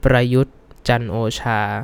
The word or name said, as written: Prayut Chan-o-cha